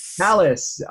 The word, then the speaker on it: Halasz: Halasz.